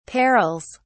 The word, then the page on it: perils: British English